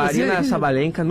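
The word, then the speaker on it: Aryna Sabalenka: A Arina Sabalenka, número um do mundo de Wattia.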